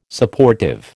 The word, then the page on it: Supportive: American English